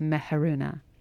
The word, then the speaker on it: Meharoona: My name is Meharoona Ghani, and my first name has three meanings, depending on pronunciation. It can mean love or sun or light of the moon, and I have chosen light of the moon, so that's why I pronounce it as Meharoona.